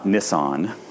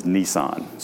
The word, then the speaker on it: Nissan: So for starters, their first month is Nisan, which is why I pronounce the car company this way, for those of you that wonder. It's not Nissan. This is Nissan. So when I see you driving a vehicle and it's a Nissan, I pronounce it Nissan, because that's my Jewish joke.